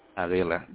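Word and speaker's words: Padilla: Palila, that is correct.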